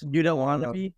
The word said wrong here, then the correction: Watanabe: Yuta Watanabe. – Yuta Watanabe.